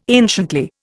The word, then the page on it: anciently: Indian